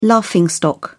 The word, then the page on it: laughing stock: British English